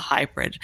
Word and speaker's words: HAIbrid: Here we come on the hybrid. And that's spelled H-A-I-B-R-I-D.